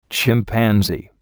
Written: chimpanzee

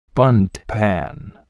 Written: bundt pan

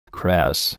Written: cress

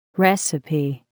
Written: recipe

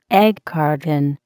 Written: egg carton

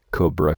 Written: cobra

